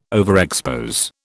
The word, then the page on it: overexpose: British English